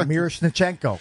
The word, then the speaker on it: Miroshnichenko: You know the guy I'm talking about, Miroshenko or whatever. – Miroshnichenko. – Yes. – Who had a little bit of time up here last year. – Forgot a couple syllables.